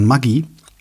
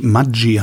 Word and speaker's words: Maggi: Und die hat sich für mehrere Sendungen, ging es äh, um die korrekte Aussprache von Maggi. Kennst du, ne? Dieses Würz. – Maggi die Würze. – Genau. Ja. Die mhm. anscheinend tatsächlich Maggi heißt. – Nein. – Was aber nur die Schweizer sagen.